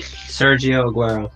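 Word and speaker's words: Sergio Agüero: Sergio Aguero.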